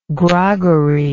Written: groggery

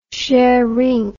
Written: szeryng

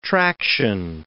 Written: traction

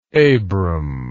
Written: abram

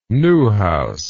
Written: newhouse

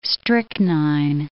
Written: strychnine

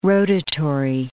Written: rotatory

